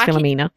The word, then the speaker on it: Philomena: And ever since then, I keep thinking of it as Philomania and it isn't. It's Philomena.